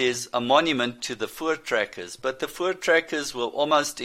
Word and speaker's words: Voortrekkers: And Voortrekkers is spelt with a V, but it's pronounced as an F.